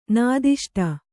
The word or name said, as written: ನಾದಿಷ್ಟ